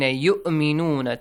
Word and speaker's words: يؤمنون: Try not to pronounce it as يؤمنون. Hamza is there, okay? That waw is just like a chair or something, like a base for it, okay? Not nothing else, okay? You gotta pronounce it as Hamza.